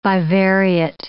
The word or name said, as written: bivariate